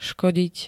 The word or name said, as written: škodiť